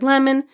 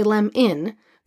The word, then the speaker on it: lemon: So for example, in the word lemon, there's a schwa. We say lem in, but we spell it lem on.